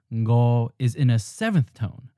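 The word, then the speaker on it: ngo (五): The literary pronunciation for five is ngo. Ngo. It ji sam su ngo. – Notice that it begins with a ng nasal sound that we don't have at the beginning of words in English, but it does end words like the word sing.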